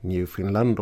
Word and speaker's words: Newfoundland: Ehm, det som händer är i alla fall att SS Arctic uppe vid Newfoundland eller Newfoundland då om man ska använda ett kanadensiskt uttal.